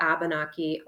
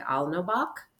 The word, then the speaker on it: Alnobak: So, yes, these are the ancestral lands of the Abenaki, or um, sort of more correct pronunciation sounds a little more like Alnobak um, in the Abenaki language.